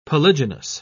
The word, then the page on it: polygynous: British English